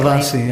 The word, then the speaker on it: pravasi: The term pravasis comes up a lot, if I'm pronouncing that correctly. – Pravasi, yeah. – Pravasi. – Yeah, that's very good.